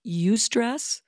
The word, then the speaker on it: eustress: It's not a word I've ever used in clinical practice, but it's called eustress, and it's spelled E-U, stress. You say you.